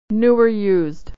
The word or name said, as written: or used